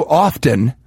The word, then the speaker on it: often: It happens so often, because I don't pronounce the T in that word, that whenever I say often, I go to the mirror and I get real mad.